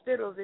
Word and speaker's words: Spittlez: Just Google Spittlez if you have to, and you will definitely get a hold of me.